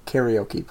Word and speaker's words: karaoke: You'd think if you walk into a karaoke place and say, ni, which means two, and karaoke or karaoke, which is the probably the more proper way to say it. – Somewhat more right pronunciation, that someone could infer that these two people would like karaoke.